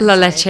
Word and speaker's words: La Leche: Um, I joined what is it like La La Lache League? I can't pronounce it. La Leche, yeah.